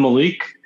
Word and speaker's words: Malik: Okay, well, I'm Malik, M-A-L-I-K.